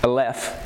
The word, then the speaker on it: Aleph: Alpha, that's Aleph, is how they pronounce it.